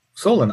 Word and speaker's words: Solon: And to give you the background on Solon, I based Solon on a historical character. I based it on the character Solon, S-O-L-O-N. Now, in the script, it's spelled S-O-L-A-N.